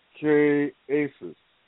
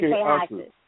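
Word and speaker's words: Qué haces: So the H is silent, so pronounce it without the H being sounded. Silent. – K aces. – Well, you said K haces.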